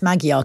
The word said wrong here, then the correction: Magyar: correctly. Are you proud of me? – Magyar, not quite. There's more a D than a G, I'm afraid.